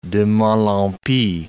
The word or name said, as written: de mal en pis